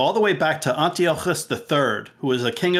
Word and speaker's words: Antiochus: Not sure if I'm pronouncing that correctly. – That was perfect. Perfect. – Yes. – Antiochus. – Thank you. – In the show, they actually pronounce it a little differently, but Antiochus is the way that it is pronounced.